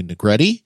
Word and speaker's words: Negrete: written by showrunner Angela Kang and co-executive producer Matthew Negrete, which rhymes with spaghetti, and directed by Greg Nicotero.